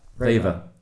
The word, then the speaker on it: REWE: Reve. Is that Reve? Reve. What do you mean by Reve? – Reva? – The shop? – Yeah, the shop. – Oh, yeah, Reva. Yeah. – Reva? Have I been saying it wrong for 8 months? – R-E-W-E. – Yeah, that's yeah. – it. – Yeah, Reva. – Okay. – Sounds a bit like Raver.